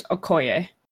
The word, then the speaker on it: Okoye: But I'm pretty sure it's Okoye.